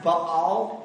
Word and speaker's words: Baal: It's actually pronounced Baal in the Hebrew, or it's Baal. It's two A's. You've got to pronounce them both, Baal. But we're from the South, so we say Baal. Like Jethro and Baal. But we'll call him Baal.